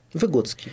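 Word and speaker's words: Выготский: Вот вы упоминали сегодня, я все время забываю, как правильно ударение, Выгодский, да? Выгодский, да. Отлично, можно работать на радио.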